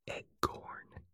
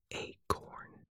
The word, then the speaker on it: acorn: I always said eggcorn when I would say the, instead of saying the word acorn. I don't know why. I think I've always done it since I was a kid. I said eggcorn instead of acorn.